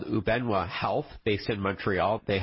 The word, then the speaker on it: Ubenwa: For our listeners, it's spelled U-B-E-N-W-A, Ubenwa.